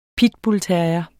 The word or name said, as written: pitbullterrier